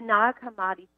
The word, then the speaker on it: Nag Hammadi: I think, and I look to you for pronunciation. I've heard it Nag Hammadi or Nag Hammadi. – Yes. The Nag Hammadi finding,